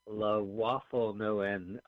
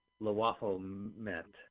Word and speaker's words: Lwaflmoyt: I'm going to pronounce Lwaflmoyt. Got it. There's no N at the end. I added that. Lwaflmoyt.